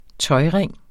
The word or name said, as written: tøjring